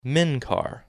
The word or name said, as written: Menkar